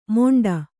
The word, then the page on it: ಮೊಂಡ: moṇḍa